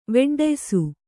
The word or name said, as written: ವೆಡ್ಡಯ್ಸು